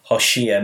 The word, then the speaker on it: Hoshea: You know, the name Hosea, pronounced in the Hebrew Hoshea, means salvation.